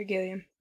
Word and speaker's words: Gilliam: among which were Terry Gilliam? Gilliam? Is that how you say that? – Terry Gilliam.